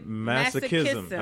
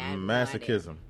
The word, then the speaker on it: masochism: Masochism? – Masochism. I think the C-H is like a K. – Okay. – Masochism.